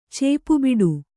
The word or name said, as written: ಚೇಪುಬಿಡು